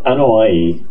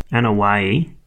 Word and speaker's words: Anoa'i: Anoa'i. It's A. – Anoa'i.